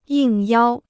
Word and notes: 应邀: yìng yāo

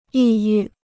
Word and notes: 抑郁: yì yù

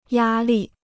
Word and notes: 压力: yā lì

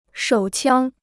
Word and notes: 手枪: shǒu qiāng

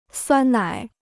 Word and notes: 酸奶: suān nǎi

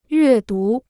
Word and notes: 阅读: yuè dú

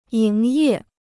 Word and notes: 营业: yíng yè

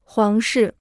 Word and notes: 皇室: huáng shì

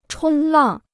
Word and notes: 冲浪: chōng làng

